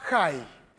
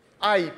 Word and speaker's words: Ai: Y cerca de Jericó había otra ciudad, una ciudad chiquita, que se llamaba Jai, o H-A-I. Usted pronunciarlo como quiera. Ai, pueden decir. Jai.